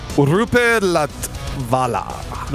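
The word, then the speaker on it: Roope Latvala: Is it like Roop or? – Rupe Latvala.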